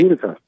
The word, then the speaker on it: Utica: And is it Utica? How do you pronounce it? Utica. – Utica. Oh, okay. – That's me.